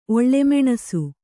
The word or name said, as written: ಒಳ್ಳೆಮೆಣಸು